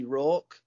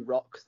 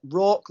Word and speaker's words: Rawk: Yeah, so if you want to follow me on Facebook and or Twitter, I am at Scotty Rawk, not Scotty Rock, that a lot of announcers seem to mispronounce my name. Rawk like Hawk, just think Tony Hawk pro skater, but it's Scotty Rawk pro wrestler.